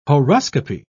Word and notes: horoscopy: British English

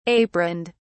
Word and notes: aproned: British English

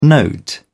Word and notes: note: British English

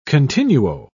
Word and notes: continuo: British English

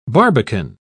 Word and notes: barbican: British English

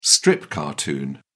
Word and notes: strip cartoon: British English

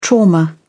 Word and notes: trauma: British English

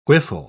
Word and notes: whiffle: British English

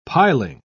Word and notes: piling: British English

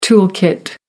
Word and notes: tool kit: American English

